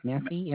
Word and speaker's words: Massey: I have for the first name Betty, and that's spelled B-E-T-T-Y. Last name is, I believe pronounced Massey, and that's Massey. – Spelled M-A-S-S-E-Y.